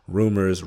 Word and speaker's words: RMR: And so he has me listening to Breland with My Truck, Rumors, it's spelled RMR though, but Rumors,